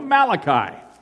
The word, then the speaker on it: Malachi: Now, for a long time, I, I didn't know who this guy Malarkey was that the preacher was talking about, or Malachi. I, I didn't understand it, and then I realized later on in my development how to pronounce his name properly.